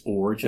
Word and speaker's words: Iorg: Well, the first time that I called over, I said, may I speak to Dr. Jorg? And immediately his assistant corrected me and said, it's Orge as in George. – I-O-R-G, right? – Yeah, but it's spelled I-O-R-G.